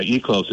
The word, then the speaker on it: Eclos: Yeah, Eclos. It's spelled E-C-L-O-S.